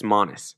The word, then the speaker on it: Amanis: Anyway, Amanis is pronounced like the word honest, but with a silent T. Honest Amanis.